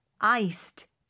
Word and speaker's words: iced: I-C-E-D. – 네, 그래서 뜨겁게 hot 혹은 iced. 아이스로 라는 표현인데 iced 뒤에 d가 붙죠. 그래서 I-C-E-D가 들어가는데 발음은 iced 이렇게 t 발음으로 됩니다. iced가 아니에요. iced 이렇게 발음이 됩니다. 자, 그랬더니 대답으로는 ice로 주세요. – iced please. – 역시 또 iced capital I-C-E-D.